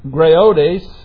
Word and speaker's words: graodes: Now, this word graiodes, which is spelled G-R-A-O-D-E-S, is really a derogatory word used for old women when they are being silly or misled.